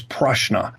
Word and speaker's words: prashna: Now, in India, that is referred to as prashna. Prashna, mm-hmm. spelled with a P, is divinational.